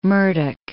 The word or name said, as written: Murdoch